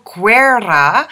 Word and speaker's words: Guerra: His name is Mario Guerra, which is what I'm told is the way you pronounce it in Italian.